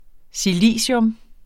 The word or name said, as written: silicium